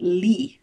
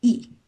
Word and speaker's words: Lee: And so you don't actually pronounce it Lee, for example, it's E.